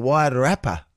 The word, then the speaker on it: Wairarapa: Please, because Ben's very anxious I do think of a good rap name, the Wairarapa.